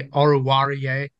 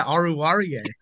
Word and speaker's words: Oruwariu: But as you said, you know, Hawkinson, Amani Oruwariu, I think that's how you say it. – Well done. – Yeah, Oruwariu. Yeah, I've heard his name a couple of times, but not too much.